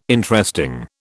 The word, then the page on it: Interesting: British English